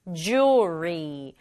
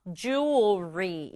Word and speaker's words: jewelry: Jewelry, jewelry. 발음 좀 어렵죠? 이 단어는 사실 두 가지로 발음할 수 있는데요. Jewelry 이렇게 발음하는 사람도 있고. Jewelry 이렇게 발음 사람도 있어요.